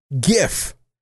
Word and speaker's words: GIF: It is pronounced GIF. It's basically gift, like in present, without the T. I've heard GIF. I've heard GIF. It's GIF. G-I-F.